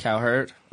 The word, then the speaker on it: Cowherd: Colin Coward, or I think that's how you say his name. – Cowherd.